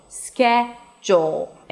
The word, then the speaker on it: schedule: Schedule, the first syllable is the primary stress, so it's higher in pitch and longer.